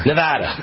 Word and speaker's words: Nevada: Nevada, I got to say it right, Nevada. They always get mad at me in Nevada if I say Nevada. It's always attaboy, Nevada, Nevada. There we go. – Nevada.